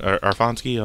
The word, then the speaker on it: Aronofsky: It was Darren Aronofsky, I'm probably pronouncing that wrong.